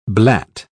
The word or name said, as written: blat